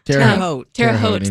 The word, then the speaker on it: Terre Haute: That one isn't, I'm going to say this wrong, Terre Haute, Indiana? – Terre Haute, thank you.